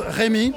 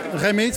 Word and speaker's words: Rémi: My name is Rémi. Rémi, spelled with an R. It's like the cognac, you know? – Ah, excellent, Rémi.